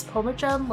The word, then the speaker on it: Pomachan: It's Pomachan, like Edgar Allan Poe, okay.